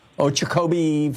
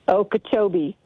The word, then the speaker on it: Okeechobee: Okeechobee, Florida. Am I saying that right? – Okeechobee.